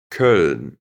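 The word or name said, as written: Köln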